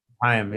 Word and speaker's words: Haim: Haim, yeah.